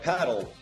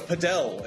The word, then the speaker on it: padel: Yeah, it's like, and people get real offended if you're like paddle, and they're like, no, it's padel, and like they get real all offended if you mispronounce one of these made up games, Brett.